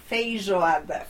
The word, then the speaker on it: feijoada: Feijoada.